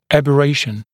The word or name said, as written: aberration